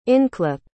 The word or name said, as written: inclip